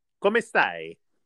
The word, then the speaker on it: Come stai: Come stai? – Say it really slow, like you're teaching someone.